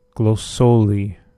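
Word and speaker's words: Glósóli: Glossoli, I believe, is the pronunciation of that. It's Icelandic.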